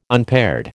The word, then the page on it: unpaired: American English